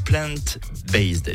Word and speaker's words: plant-based: Alors qu'est-ce qu'un régime plant-based? Est-ce que je le prononce bien déjà? – Oui, très bien.